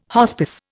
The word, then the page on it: hospice: Indian